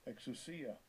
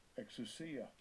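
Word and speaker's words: exousia (ἐξουσία): Strong's G, 1849, exousia, exousia.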